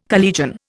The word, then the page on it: collegian: Indian